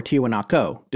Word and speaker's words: Tiwanaku: Tonight is the first of the two parts on Pumapunku, which is a component of a larger site in Bolivia known as Tiwanaku. Or Tiwanaku, depending on how you wish to pronounce it. Either way is correct.